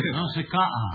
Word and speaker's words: Nausicaa: Is that pronounced Nausicaa or Nausicaa, John? – I'll leave that to Frank. – Nausicaa. – You like Nausicaa better? – No question.